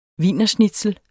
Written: wienerschnitzel

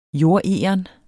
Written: jordegern

